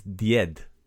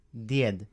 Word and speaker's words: Djed: But grandfather is Djed. – Djed. – So last episode, we learned how to say Santa Claus, which is Djed Mraz. So grandfather, that's the word for grandfather. Djed. – Djed. – That's spelled D-J-E-D.